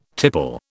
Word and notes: tipple: British English

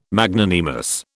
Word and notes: Magnanimous: British English

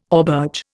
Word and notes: auberge: Indian

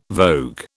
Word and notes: vogue: British English

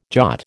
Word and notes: jot: American English

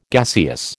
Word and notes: gaseous: American English

